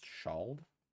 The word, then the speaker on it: Ceald: It's spelled CEALD. I guess it's pronounced shawled.